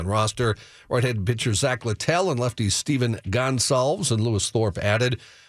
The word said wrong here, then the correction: Gonsalves: Gonsalves. – What did I say? – Gonsalves. – That's you put the wrong emphasis on the wrong syllable.